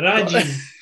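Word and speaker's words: radge: And you put a D before the G, so it's R-A-D-G-E, Raj.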